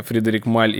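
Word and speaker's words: Frédéric Malle: Ну, в общем, у нас сегодня герои Кира, это пиар-директор, пиар-менеджер брендов, э, Джо Малон, э, Фредерик Маль или Лабо. – Да, все я при... все правильно сказал? – Да, все верно. – Мы долго переживали, что я не выговорю Фредерик Маль и Джо Малон.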